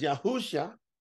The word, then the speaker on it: Yahusha: So Yahusha, or Yahusha, I'm breaking it down in syllables so you get it, but I pronounce it as Yahusha.